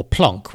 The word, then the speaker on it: Planck: Anyway, fast forward a few more decades, and we find the famous Max Planck, or Planck, which is apparently how he would pronounce it in his native German,